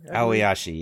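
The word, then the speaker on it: Aoashi: Moving quickly along, I've been watching the sports anime Ao Oshi. I think that's how it's pronounced. It's A-O-A-S, or I can't even spell it, but it's a pretty good soccer anime. – Aoyashi, yes.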